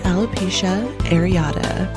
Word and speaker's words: alopecia areata: So if anyone is wondering who's listening right now and hasn't quite gotten the pronunciation down yet, it's alopecia areata, or alopecia totalis, or alopecia universalis.